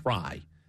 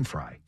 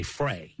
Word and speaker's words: Frey: So this young woman, Anna Fry, it's spelled, by the way, Frey, but I did my research. It's Fry. – Yeah, like Glenn Fry. – Yeah. Yeah, but some people might say Frey.